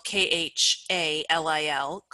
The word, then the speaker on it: Khalil: Um, my last name is spelled K H A L I L, Khalil, mm-hmm.